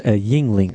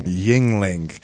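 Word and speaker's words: Yuengling: Yes, it's pronounced Yingling. – Yingling.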